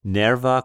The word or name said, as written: Nerva